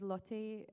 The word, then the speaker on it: Lotte: So I'd heard my friend Lotte, as I try and pronounce it the Dutch way, Lotte Verbeek, who's an Outlander, had always said to me how nice Graham was.